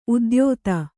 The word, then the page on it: ಉದ್ಯೋತ: udyōta